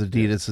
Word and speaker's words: Adidas: Because A.D.I.D.A.S is a big soccer brand. The founder's name was Adi Dassler. A.D.I., second name, D.A.S.S.L.E.R. That's where A.D.I.D.A.S comes from. – In Europe, they pronounce the brand name Adidas.